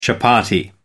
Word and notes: chapatti: American English

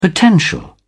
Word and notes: potential: British English

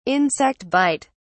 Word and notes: insect bite: British English

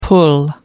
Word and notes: pull up: American English

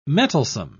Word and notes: mettlesome: British English

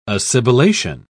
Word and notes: assibilation: British English

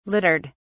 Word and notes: littered: British English

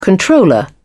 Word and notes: controller: British English

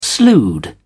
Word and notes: slewed: British English

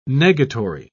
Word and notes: negatory: British English

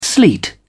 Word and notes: sleet: British English

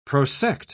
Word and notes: prosect: British English

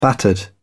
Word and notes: battered: British English

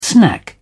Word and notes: snack: British English

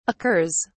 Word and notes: occurs: British English

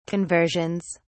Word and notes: conversions: British English

